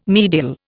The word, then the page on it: medial: Indian